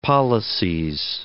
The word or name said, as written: pollices